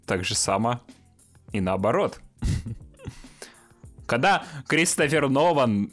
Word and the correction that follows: Нолан: но, но, Нован, Нолан,